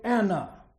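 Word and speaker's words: Anna (אנא): That word beseech comes from the Hebrew Anna. It's spelled like the name Anna, A-N-N-A.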